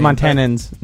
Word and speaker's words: Montanans: Because I feel like all Montanians, excuse me, Montanians that are listening. – Montanans, not Montanians. Don't add an extra syllable.